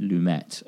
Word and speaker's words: Lumet: And I know some people say Sidney Lumet, and I used to too, but then I watched a round table where Christopher Nolan pronounced it Lumet, and I figured that he knows, so Lumet it is.